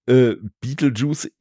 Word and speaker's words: Betelgeuse: Ja, die Sache ist die, Beetlejuice ist ja die englische Aussprache von dem Stern, den wir als Betelgeuse bezeichnen.